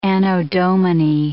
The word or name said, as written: anno Domini